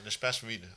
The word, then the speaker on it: l'espace vide: And you've talked as well about l'espace vide. Is that how you say it in French? The empty space? – Yeah, the empty space. Yeah, yeah, l'espace vide, I think.